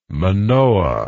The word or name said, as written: manoah